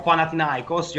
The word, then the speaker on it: Panathinaikos: attualmente attaccante del Panathinaikos. Vediamo se riusciamo. Panathinaikos, io non sono greco, quindi alzo le mani. – L'hai detto bene.